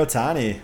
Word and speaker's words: Ohtani: But, you know, they've got, I'm going to say his name wrong, Ohtani. – Shohei Ohtani.